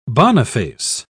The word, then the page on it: boniface: British English